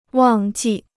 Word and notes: 忘记: wàng jì

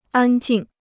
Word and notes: 安静: ān jìng

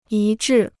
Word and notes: 一致: yī zhì

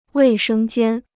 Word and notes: 卫生间: wèi shēng jiān

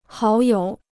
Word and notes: 好友: hǎo yǒu

